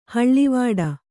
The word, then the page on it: ಹಳುವಡು: haḷuvaḍu